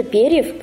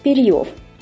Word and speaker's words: перьев: Вы наверняка заметили проблему с ударением. Вместо перьев прочиталось перьев.